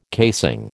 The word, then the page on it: casing: American English